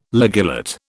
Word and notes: ligulate: British English

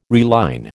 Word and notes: reline: American English

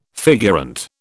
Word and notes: figurante: British English

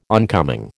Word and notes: oncoming: American English

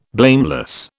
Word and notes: blameless: British English

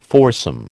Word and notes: foursome: American English

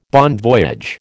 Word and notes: bon voyage: American English

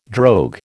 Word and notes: drogue: American English

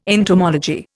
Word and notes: entomology: Indian

